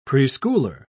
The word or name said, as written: preschooler